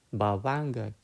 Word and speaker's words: Bhavanga: So the low level mind, that low level citta, in the Pali language is called the Bhavanga Chitta. Okay, Bhavanga Chitta. It is spelled B H A V A N G A.